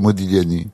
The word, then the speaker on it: Modigliani: Vous dites quand vous voulez, mais on doit pas prononcer le G. – Non, c'est Modigliani.